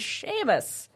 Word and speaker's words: Seamas: He's like, it's Seamas. I'm like, oh. I had never heard it, didn't know how to pronounce it. So it is Seamas.